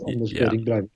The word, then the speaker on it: Anders Breivik: Yes, Anders Breivik.